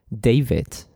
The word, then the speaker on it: David: So, for example, my name is David, and I am going to spell it. Voy a deletrearlo. D-A-V-I-D.